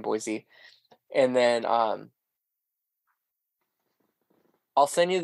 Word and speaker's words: Boise: It's right? Boise. It's Boise. Like, it's pronounced like the word boy and then the letter C.